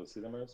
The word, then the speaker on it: Sesamers: Sizamers. Did I pronounce that right? – Yeah, you can pronounce it this way. We tend to say Sizami, you know, like so Sizamers, but yeah.